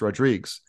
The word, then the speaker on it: Rodrigues: Because my last name is the Portuguese version of Rodriguez, which is pronounced Rodrigues. Depending on where you're from.